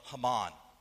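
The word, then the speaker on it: Heman: The author of this psalm is a man by the name of Haman. It's spelled like He-Man, but it's actually pronounced Haman.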